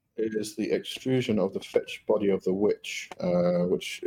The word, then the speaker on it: fecce: fetch. Now the fetch, as far as I remember, is an Anglo-Saxon term spelled F-E-C-C-E and pronounced fetch.